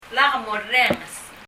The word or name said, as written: Lak morrenges